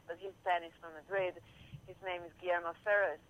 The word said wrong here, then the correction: Fesser: I'm sure you would pronounce it right. – Guillermo Fesser. He's gonna be. He will be. Fesser.